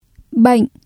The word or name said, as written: bệnh